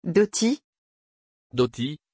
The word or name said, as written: Dottie